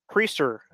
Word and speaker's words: Priester: Who's their big, Quinn, is it Quinn Priester? Is how you pronounce his name? – Priester, actually. – Priester. – Yep.